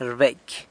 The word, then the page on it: rbecy: sanlucasquiavini